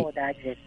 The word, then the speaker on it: 高台寺: これはね、そうです、高い二代と書いてね、高大寺です。